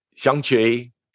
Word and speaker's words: Shang-Chi: is it Shang-Chi? – Shang-Chi and the Legend of the Ten Rings.